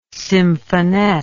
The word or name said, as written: symphonette